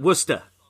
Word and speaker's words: Worcester: Worcester is spelled Worcester, like Worcester sauce, but they just they call it Worcester.